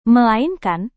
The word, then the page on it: melainkan: Indonesian